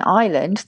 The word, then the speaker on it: island: So to this day, we have the S in island, the silent S in island, because they thought it came from the Latin insula.